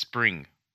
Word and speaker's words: XSpring: There's no S. It's just XSpring. – Yeah, say that without saying it. But it's pronounced Spring.